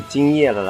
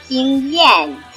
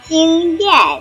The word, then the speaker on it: 经验: 拜托，这是人家第一次了啦，人家没有经验了啦。 – 小伙子，发音要标准哦，是经验，经验。